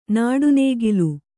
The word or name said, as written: ನಟನಕಲೆ